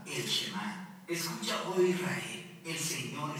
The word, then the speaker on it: Shema: La primera palabra del Shema es oye o escucha, y en hebreo se pronuncia Shema.